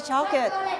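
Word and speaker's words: chocolate: ช็อกเกตช็อกเกต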